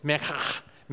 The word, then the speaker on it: minchah: The Hebrew word that is translated grain or meal or cereal or meat here is the word minchah, M-I-N-C-H-A-H. And actually, to pronounce this Hebrew word, you almost have to pronounce to clear your throat. It's mechach, mechach. That's the way it's pronounced.